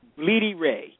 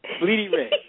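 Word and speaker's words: Blidi Wreh: And his middle name is spelled W-R-E-H. Now, and what's his last name, Jenkins? – No, it's William. But his name is pronounced Bleedy Ray.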